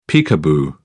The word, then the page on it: peekaboo: British English